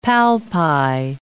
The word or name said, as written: palpi